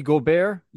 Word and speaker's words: Gobert: Rudy Joubert had a bit of a... – That's one way of pronouncing it, but... – Rudy Gobert? – Yeah, there you go.